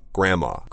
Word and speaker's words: grandma: But when we're talking about our grandparents, we really don't pronounce the N-D. – Oh. – That's the whole point, is like when I talk about my grandma and grandpa...I will spell it G-R-A-N-D-M-A,、but I will always say grandma.